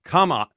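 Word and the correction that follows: Kamiah: is actually pronounced Kamiai.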